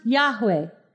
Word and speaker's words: Yahweh: So the name of God is four letters in Hebrew, and it's pronounced Yahweh.